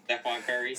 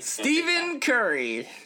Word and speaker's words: Stephen Curry: I mean, come on, Stephon Curry. – Stephen Curry.